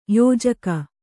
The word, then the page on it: ಯೋಜಕ: yōjaka